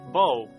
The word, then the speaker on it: 剝: In Mandarin, the word meaning to peel is pronounced Bo.